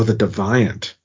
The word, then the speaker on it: Deviant: I believe it's pronounced The Deviant. – Oh, The Deviant. – Yeah, The Deviant. You know, just like the USS Defiant. It is The Deviant.